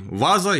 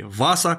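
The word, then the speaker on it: Vasa: И вот шведский король Густав I Ваза, или Васа, как правильно говорить, начал создавать постоянную армию.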